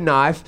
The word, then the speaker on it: knife: I think the word knife is scary in itself because the K is silent and is concealing a knife.